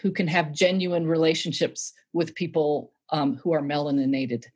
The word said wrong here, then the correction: melanated: Am I saying that right? I'm laughing. – Melanated.